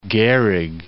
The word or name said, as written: Gehrig